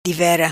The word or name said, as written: Divera